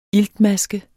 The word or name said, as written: iltmaske